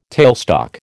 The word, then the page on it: tailstock: American English